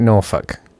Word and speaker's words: Norfolk: I make the point that you don't pronounce Norfolk, you pronounce it Norfolk.